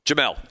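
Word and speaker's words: Jemele: Jamel.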